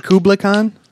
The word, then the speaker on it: Kublai Khan: Kublai Khan? – Yeah.